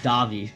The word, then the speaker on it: Davy: It's actually pronounced Davy.